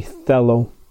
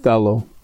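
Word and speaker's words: thelo (θέλω): It's actually thelo. And so we go back. The proper pronunciation of this word is thelo.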